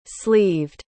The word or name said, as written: sleeved